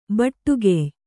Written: ಭಟ್ಟಿಗೆಯ್

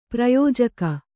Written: ಪ್ರಯೋಜಕ

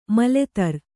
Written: ಮೊಲೆತೊರೆ

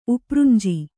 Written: ಉಪ್ರುಂಜಿ

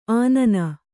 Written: ಅನ್ನ

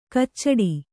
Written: ಕಚ್ಚಡ

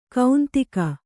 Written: ಕೌಂತಿಕ

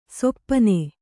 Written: ಶಪನ

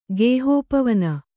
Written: ಗೇಹೋಪವನ

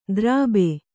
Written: ದರ್ಭೆ

